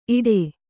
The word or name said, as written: ಇಡೆ